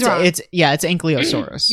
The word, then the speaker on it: Ankylosaurus: It's Ankylosaurus. – Yeah, he's wrong. – I was like, no one told this kid it's not called Ankylosaurus.